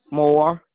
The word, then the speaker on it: Moore: Moore. – Okay. How does it spell? M-O-R? – M-O-O-R-E. – M-O-O-R-E? – Yes.